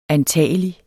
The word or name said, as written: antagelig